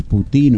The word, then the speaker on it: Putin: Acá dicen Putin, pero no sé si es Putin o no, pero el nombre, el apellido en ruso es Putin, en realidad con acento en la U.